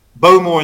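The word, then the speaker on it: Bowmore: So if we look at Beaumore, if you're in the know and you're from Islay, Beaumore is actually pronounced Beaumore. – Oh, right. – So you, it's like it's more hurried emphasis. Along a bit. So it's Beaumore, not Beaumore. It's Beaumore. And our friends up in Tain, Glenmorangie, is similar.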